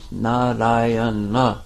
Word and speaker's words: Narayana: Narayana, four syllables, and he was calling his son.